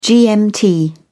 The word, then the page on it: gmt: British English